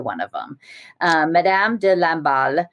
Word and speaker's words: Lamballe: I just said to Claudine, it sounds like Rambo or something, but it's like Lamballe, like a ball.